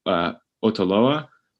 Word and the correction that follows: Otaola: Otaola, yeah.